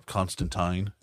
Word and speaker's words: Constantine: Like, we do have John Constantine, or Constantine, as it's properly pronounced.